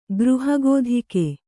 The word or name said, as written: ಗೃಹಗೋಧಿಕ